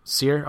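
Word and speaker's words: Cyr: I would think that's Sear. – Sear. Okay, Sear by the Smashing Pumpkins.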